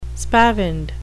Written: spavined

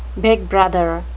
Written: big brother

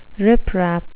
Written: riprap

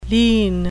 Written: lien

